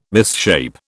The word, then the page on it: misshape: British English